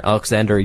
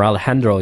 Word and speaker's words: Alejandro: writer extraordinaire Alexander Jodorowsky, um, or Alejandro Jodorowsky, I guess it's properly pronounced, who did such great movies as El Topo, Santa Sangre, The Holy Mountain, and a couple other ones.